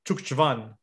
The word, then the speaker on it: Zugzwang: Z-U-G, as in George, Z-W-A-N-G. I think it's pronounced Chukchvan.